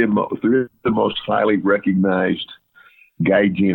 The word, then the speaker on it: Gaijin: I know it's G-I-G-A-N-G-I-N, the top American or foreigner. – Oh, Gaijin. – Gaijin. Yeah, Gaijin.